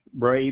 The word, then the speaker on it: Bray: Jack Bray, B-R-A-Y, 11054.